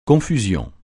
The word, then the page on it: confusion: francais